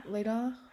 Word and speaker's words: Latah: And that's Latah. Am I saying that right? – Yeah. – Latah.